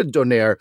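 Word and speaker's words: döner: Now, if you've had a doner, and I'm no longer talking about the Turkish doner, and I think it's pronounced doner. – Doner. Yeah, that's what How do you pronounce the O with the two dots on it?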